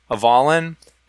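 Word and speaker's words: Avallen: It's called Legends of Avalon, and that's A V A L L E N. I'm not just mispronouncing Avalon.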